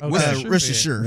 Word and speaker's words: Worcestershire: What is the proper way? I'm not looking at your. – No, you said it right. Worcestershire.